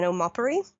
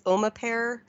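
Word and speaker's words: Omapere: specifically Opononi and Omapuri. It looks like the way to pronounce them, that's how the correct pronunciation. When I first saw it, I thought it was Omapare and like Opanani or something, but they're about an hour and a half drive from Kerikeri.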